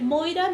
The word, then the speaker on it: Moira: Estuve a punto en algún punto de llamarme en Instagram Moira con Y, ¿ah? porque la gente siempre me pone con Y latina, yo soy con Y. Mi nombre es Moira, mi apellido es Cheliu, se pronuncia así, si usted no lo sabía, porque yo sé que mi apellido es raro.